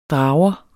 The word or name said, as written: drager